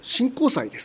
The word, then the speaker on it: 神幸祭: その伝承を再現したのが 7 月の第 2 日曜日の神幸祭です。神にですね、幸は幸福の幸、幸ですねと書きて神幸祭と読むんですが、この幸は移動するという意味で、神様を乗せたおみこしが移動することを意味するんですね。